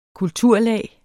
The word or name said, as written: kulturlag